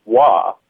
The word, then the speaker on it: oie: the word for goose is wa. It's it's spelled O I E, but it's pronounced wa.